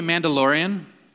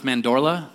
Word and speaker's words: Mandorla: I always say Mandalorian, but it's not. It's Mandorla. There's a word Mandorla, but since this stupid Star Wars thing, I'm always saying Mandalorian.